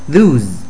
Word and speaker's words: lose: 另外 lose 它是发 z 的音，不要念成 s 的音，不然就会变成另外一个单字松垮垮的意思哦。大家加油，多练，一定会进步的。我们下次再见。